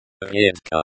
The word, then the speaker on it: редко: Редко, the stress is on the second syllable, the e, it sounds like редко.